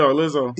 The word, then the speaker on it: Lizzo: that's why I love Lizzo. So am I saying that right? Yeah, Lizzo.